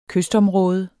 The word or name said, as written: kystområde